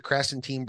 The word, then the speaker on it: Krasenstein: And these guys, and, you know, the to a lesser extent, like the Krasenstein brothers, you know, am I pronouncing their names correctly? – Krasenstein. Yeah.